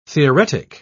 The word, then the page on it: theoretic: British English